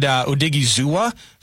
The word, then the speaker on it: Odighizuwa: They added Odighizuwa from Dallas.